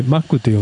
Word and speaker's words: MAC: 松尾さん的なネタの拾いでいきますと、化粧品の MAC って書いてマックと読む、メイクアップアートコスメティック社がこの 85 年にカナダで創業されてるみたいです。